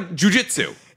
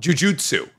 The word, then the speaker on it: Jujutsu: That's just what I... Jujitsu. – No. – Jujutsu. – Yes. – I refuse. – Jujitsu. – Is the fighting style. – Yes. – Jujutsu Kaisen. – Look it up. Look how it's spelled. – No. I know it's jujutsu.